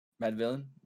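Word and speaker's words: Madvillain: Um, yeah, probably Mad Villian or like I love Mad, wait, Mad Villian? – Yeah, that's what I said, right? Mad Villian? Mad Villain?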